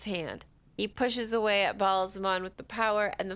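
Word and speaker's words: Ba'alzamon: This is not great news to Rand, who continues to deny, but I never say his name right. who continues Baal-Zamon.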